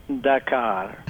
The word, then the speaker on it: Dakar: I believe they would pronounce it Dakar. because uh, I guess the letters are the same, or? – Those five letters that make up Dakar are pronounced the same in Turkish as they are in English.